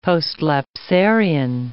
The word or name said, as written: postlapsarian